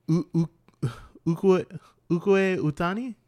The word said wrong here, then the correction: Ikue Otani: Otani? Romanized, it's Ikue Otani.